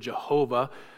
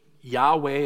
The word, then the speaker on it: Yahweh: It's unlikely that the word Jehovah correctly encapsulates what the name is supposed to be. The English Yahweh is probably as close as you're going to get, but I can almost guarantee you that you're going to pronounce it differently than Moses did.